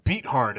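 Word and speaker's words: Beathard: They're going to get, like, four turnovers off CJ Berthard today. – Berthard. – Fucking sucks. – Talk into your microphone, Kyle. – I like Berthard, though. – Beat hard is how you say it.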